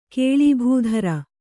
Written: ಕೇಳೀಭೂಧರ

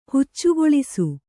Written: ಹುಚ್ಚುಗೊಳಿಸು

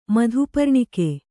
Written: ಮಧುಪರ್ಣಿಕೆ

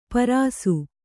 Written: ಪರಸು

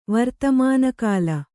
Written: ವರ್ತಮಾನಕಾಲ

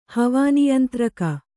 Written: ಹವಾನಿಯಂತ್ರಕ

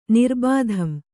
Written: ನಿರ್ಬಾಧಂ

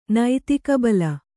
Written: ನೈತಿಕಬಲ

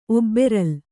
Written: ಒಬ್ಬೆರಲ್

